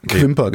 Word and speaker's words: Quimper: Quimper, spricht man das aus. – Quimper, genau.